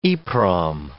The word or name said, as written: EPROM